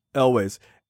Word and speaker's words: Elwes: Elwes.